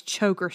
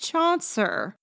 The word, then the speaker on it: Chaucer: But I don't get why I have to learn about all this choker stuff, anyways. Choker? Oh, Kenny, it's pronounced Chaucer.